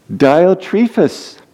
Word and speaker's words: Diotrephes: Diotrephes. That's how I choose to pronounce it. You may have a different opinion, but so I learned about him this week.